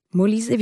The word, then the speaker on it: Molise: Molise, wie ich würde Molise sagen, weil das ein Italiener ist. – Guck mal, ich hätte es französisch ausgesprochen.